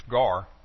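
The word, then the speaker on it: gar (γάρ): In Greek it's gar. We would spell it G-A-R.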